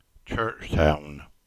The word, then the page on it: Churchtown: English